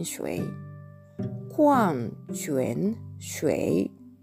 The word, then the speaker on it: 矿: これはですね、漢字で書くとですね、あの、まず 3 つの漢字で構成されていまして、最初の文字は、コアン、コアンというふうに発音します。石に、日本語で言うところのまだれみたいなのがついてるんですね。これで、ホアン、発音記号は K-U-A-N-G というふうに綴ります。